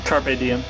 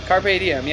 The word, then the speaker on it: Carpe Diem: How do you say it? – Carpe Diem. – Carpe Diem. Yeah, that's it.